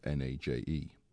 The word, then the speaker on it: Nagy: Nagy. Irv's a pal of mine. Nagy, spelt N-A-G-Y, pronounced N-A-J-E.